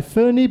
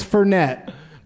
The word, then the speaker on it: Fernet: And he goes, "As I sit there drinking my Fernie Blanca," and it's Fernet is how it's pronounced.